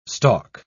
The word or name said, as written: stalk